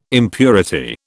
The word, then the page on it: impurity: British English